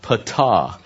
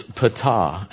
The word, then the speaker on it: Ptah: Her story begins with the god, the creation god, Ptah. So it's pronounced as though it was spelled P-A, but it's Ptah.